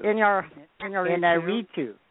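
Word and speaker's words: Iñárritu: Inarritu. – Yes. Yeah. Inarritu as Birdman, and Richard Linklater, Boyhood, Bennett Miller, Foxcatcher, and Morton Tilden, The Imitation Game.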